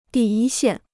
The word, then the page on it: 第一线: dì yī xiàn